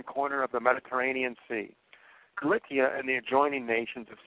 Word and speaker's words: Cilicia: Cilicia is the proper pronunciation. I don't know how it's pronounced in the mainstream. I don't care. It's in Greek, they were K's. They were hard. In Latin, they were C's, but the Romans pronounced the C as a hard letter, not as a soft letter. The soft C, like an S, is a more modern ecclesiastical invention.